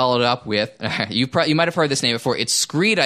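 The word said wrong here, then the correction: Scryed: I think it's pronounced. – Escryed. – Yeah, Escryed, 2001.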